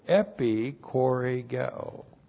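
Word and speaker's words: epichorēgeō: So epi, E-P-I-C-H-O-R, long E, G, E, long O. Epikoregeo.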